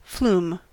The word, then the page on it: flume: American English